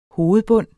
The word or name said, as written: hovedbund